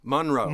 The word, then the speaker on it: Monroe: Monroe. – Jeez Louise. Rhymes with fun.